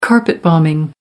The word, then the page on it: carpet bombing: American English